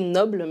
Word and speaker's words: Noble: Son nom s'écrit Noble, mais c'est Noble.